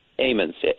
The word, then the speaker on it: Ammons: I mean, here you are, a priest, and you get a call, apparently, from, is it the Ammons family? Is that correct? – Ammons. – Ammons, okay. Ammons family. That's a long A.